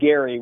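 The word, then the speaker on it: Gerry: politician named Gary with a hard G.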